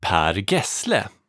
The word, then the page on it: Per Gessle: ˈpæːr ˈɡɛ̂sːlɛ; Sweden